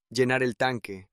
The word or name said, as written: llenar el tanque